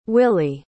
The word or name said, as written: willey